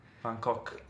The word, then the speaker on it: Van Gogh: si dovrebbe pronunciare così Van Gogh.